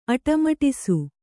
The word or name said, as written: ಅಟ್ಟಮಡಿಸು